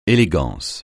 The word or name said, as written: élégance